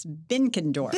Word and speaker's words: Benkendorf: That's close, Alicia. It's Benkendorf.